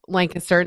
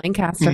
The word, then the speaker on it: Lancaster: Little town called Lancaster, and it's pronounced Lancaster, not Lancaster.